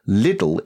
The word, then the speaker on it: Lidl: However, Lidl is pronounced like the city Leeds, a rather long I, like Lidl.